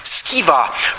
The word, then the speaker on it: Sceva: For example, you can read in Acts chapter 19 about the Jewish sons of Sceva, or sometimes pronounced Skiva, who made a kind of hilarious attempt at exorcising an evil spirit and got their hind ends kicked afterward.